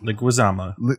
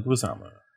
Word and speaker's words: Leguizamo: Leguizamo. – I can never say his name. I'm so sorry. – Leguizamo.